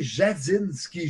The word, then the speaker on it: Jadzinski: invite notre ami Olivier Jadinski. Je le dis bien, Olivier? – Superbement, comme d'habitude.